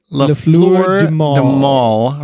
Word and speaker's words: Les Fleurs du Mal: Les Fleurs du Mal, written by Taylor Martin and Kelly Johnson and directed by Lisa Robinson.